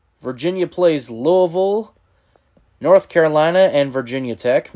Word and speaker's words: Louisville: They play Boston College, Florida State, and Louisville. – God, you say it wrong. It's Louisville. – Louisville.